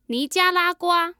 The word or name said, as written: nijialagua